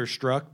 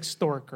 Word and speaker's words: Strzok: This guy's name is Peter Strzok, but it's spelled super wacky. So it looks like Stork or Stazork.